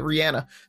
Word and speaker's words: Rihanna: So, speaking of stars, Jenna Ortega showed us how to actually pronounce Rihanna or Rihanna, because her actual name, we should be pronouncing it Rihanna.